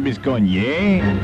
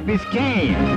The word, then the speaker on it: Biscayne: No, Lady Di, not a Biscogne. Biscayne.